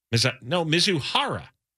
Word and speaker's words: Mizuhara: Mizuhara, okay? That's how you say it.